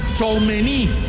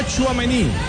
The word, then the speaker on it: Tchouaméni: Recomendaciones de los franceses: no Tchouaméni, Tchouaméni, sin el acento en la última letra, y no Barcola, Barcola. Así es la pronunciación de estos dos astros franceses.